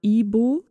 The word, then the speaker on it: Igbo: Ihre Familie gehört zur Bevölkerungsgruppe der Ibo, es wird Igbo geschrieben, aber das G spricht man nicht mit. Das ist eine, eine Bevölkerungsgruppe, die vor allem christlich ist.